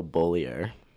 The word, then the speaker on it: Beaulieu: It's Michael Beaulieu.